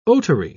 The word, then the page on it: otary: British English